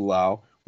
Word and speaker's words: Lowe: We've got a Josh Lowe in Tampa, and we had Nathaniel Lowe, spelled Low but pronounced Lau,